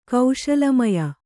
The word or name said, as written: ಕೌಶಲಮಯ